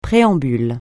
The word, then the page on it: préambule: francais